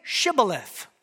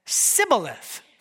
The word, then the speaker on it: Shibboleth: When he said, "No," they said to him, "Well, then say Shibboleth." That's a word that means grain. And he said, "Sibboleth," for he could not pronounce it right.